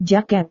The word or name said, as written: jaket